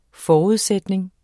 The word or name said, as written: forudsætning